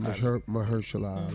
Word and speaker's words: Mahershala Ali: Mahershala Ali. – Yeah. Mm-hmm. Okay. – Say his name once so you'll know that next time. – Oh, I know his name. Mahershala Ali.